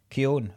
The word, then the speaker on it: Keoghan: Keoghan, is that how you say his name? – Keoghan, I thought it was, sorry.